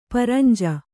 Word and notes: ಪರಂಜ: paranja

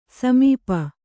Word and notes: ಸಮೀಪ: samīpa

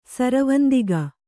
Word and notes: ಸಿರಿವಂತಿಕೆ: sirivantike

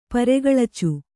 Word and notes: ಪರೆಗಳಚು: paregaḷacu

